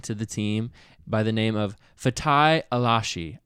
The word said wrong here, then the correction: Alashe: I think it's Alashe.